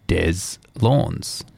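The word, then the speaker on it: Deslondes: The New Orleans-based Americana band is pronounced Des Lawns, rhymes with fawns.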